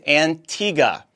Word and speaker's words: Antigua: You mispronounced it again on your Monday, January 30th update. It's pronounced Antigua. I know this because I was corrected when I traveled to the island.